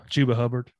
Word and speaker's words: Chuba Hubbard: Chuba Hubbard.